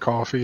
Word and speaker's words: Ko-fi: it's Ko-Fi. Somebody said it's not actually supposed to be pronounced coffee, and I'm still calling bullshit on that. – I'm going to say it's pronounced coffee.